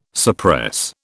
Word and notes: suppress: British English

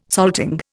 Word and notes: salting: Indian

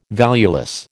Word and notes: valueless: American English

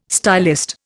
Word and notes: stylist: Indian

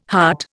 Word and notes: hut: Indian

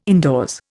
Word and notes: indoors: Indian

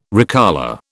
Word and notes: recolour: British English

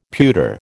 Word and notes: pewter: American English

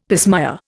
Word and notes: pismire: Indian

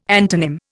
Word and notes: antonym: Indian

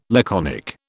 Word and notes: laconic: British English

